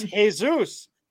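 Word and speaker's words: Jesús: It's Jesus. That's how you pronounce it, douche nugget.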